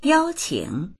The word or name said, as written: yao1qing3